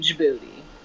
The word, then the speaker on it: Djibouti: I don't know if everybody knows this, but just in case you don't, there is a country in Africa named Djibouti. That is how you say it.